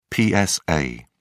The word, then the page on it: psa: British English